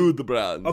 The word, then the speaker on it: Gudbrand: G-U-D. Same word. B-R-A-N-D. – Oh, I thought you were saying... – Gudbrand. – Okay, I thought you were saying Gudbrand, like G-O-O. – No, Gudbrand.